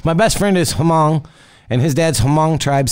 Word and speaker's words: Hmong: That's just the way they pronounce it. Hmong. It's more like there's no H either.